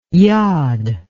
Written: yad

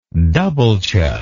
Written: dubbeltje

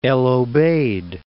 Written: El Obeid

